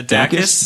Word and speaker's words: Dacus: Dacus, do you know how to pronounce that? – Yeah, Dacus, the historian album.